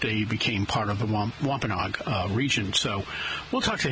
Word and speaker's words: Wampanoag: And first of all, I've been calling them Wampanoag, and I guess that's an incorrect pronunciation. It's Wampanoag. There is a difference,